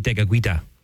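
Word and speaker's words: Tekakwitha: But again, lots to talk about and a great American saint today, St. Kateri Tikakwitha, or as it would be pronounced by her and her people, it would be St. Kateri Tikakwitha.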